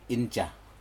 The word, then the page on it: inja: Aneityum